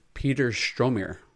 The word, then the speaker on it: Peter Stormare: but Peter Stormare.